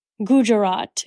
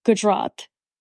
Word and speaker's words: Gujarat: And, you know, for example, it said the Ambanis or Gujarat instead of Gujarat, which is a state in India, which is where they're from.